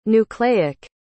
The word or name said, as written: nucleic